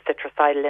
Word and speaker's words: Citricidal: They do a liquid one, and that's spelled C-I-T-R-I-C-I-D-A-L, Citricidal.